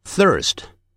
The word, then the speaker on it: thirst: 114 页，第七组 free 三或者三个 three 所以自由的念成 free 三个 three 你那个舌头一定要吐出来 three 第八鱼鳍 fin 瘦的 thin 舌头吐出来的第九第一 first 口渴 thirst 啊，要吐舌头的